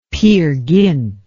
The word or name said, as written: Peer Gynt